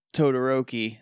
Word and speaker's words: Todoroki: The Rangers are all fighting Todoroki. – Yeah, we don't see her morph, though, because we see the Rangers all get knocked down. And then she just, like, flying shoulder checks Todoroki.